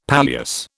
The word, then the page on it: palliasse: British English